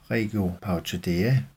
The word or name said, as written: regio parotidea